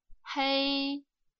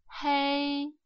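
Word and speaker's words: хэй (黑): Черный цвет произносится ровным первым тоном «хэй», «хэй».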